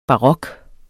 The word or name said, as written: barok